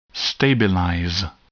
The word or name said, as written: stabilize